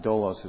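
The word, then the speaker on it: dolos (δόλος): It's pronounced dolos as best I understand.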